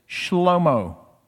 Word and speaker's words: Shlomo: It's not the case with Solomon. We say Solomon. In the Hebrew Bible, his name is Shlomo. And that's still a name that's used in Jewish culture, Shlomo.